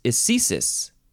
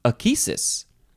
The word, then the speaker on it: ecesis: Ecesis or achesis, because it is spelled ECESIS. So some people, in, I guess, proper English, say ecesis, because that's how the C is supposed to be pronounced in this context. But some people also say achesis, which I'm guessing is from a Greek thing.